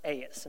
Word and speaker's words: eis: It's pronounced eis, and it's spelled E-I-S.